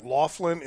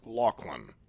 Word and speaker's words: Loughlin: It looks like Loughlin. It's pronounced Loughlin.